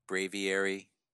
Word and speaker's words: Braviary: Hardcore, which is Bravery, Bravery, Bravery. I think I said it right, right? – Braviary. – Braviary. That's what I said.